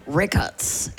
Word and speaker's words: Records: Vi udtaler det Record. – Okay. – Men det er godt at få sagt en gang for alle. – En fordanskning af Record.